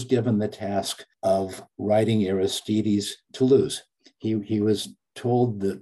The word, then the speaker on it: Aristides: The pronunciation is Aristides. – Aristides, okay, thank you.